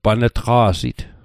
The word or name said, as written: pan'tqa'sit